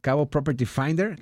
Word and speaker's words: Cabo Property Finder: Javier, digo, tú tienes la experiencia en la desarrolladora, en la comercializadora en la que tú estás, Cabo Property Finder, ¿lo dije bien? – Sí, muy bien. – Ah, bueno, siempre me falla. – Fíjate. – O corrígeme a lo mejor. – No, así es.